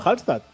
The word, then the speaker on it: Hallstatt: Pero Mira, pero pues no lo he dicho mal. Se llama Hallstatt.